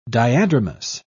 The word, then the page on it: diadromous: British English